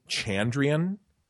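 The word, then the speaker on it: Chandrian: suggested Cards Against Chondrian. How, am I saying that right, by the way? How do you say it? – I say Chondrian, but it's very legitimate.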